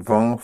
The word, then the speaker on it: vont: for the third person plural, for they, you don't have the silent ENT, but a one syllable ONT. En son vont font.